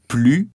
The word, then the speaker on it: plus: On prononce le S avec un nom et un verbe. – J'ai plus d'espace. On travaille plus. – Dans la phrase négative, on ne prononce jamais le S quand « plus » termine la phrase.